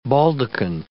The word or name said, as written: baldachin